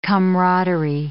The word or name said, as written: camaraderie